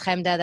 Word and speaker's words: Chemda: My name is Chemda, that's spelled Chemda, and you can find me everywhere by just putting that word into anything.